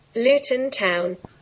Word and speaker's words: Luton Town: Luton Town.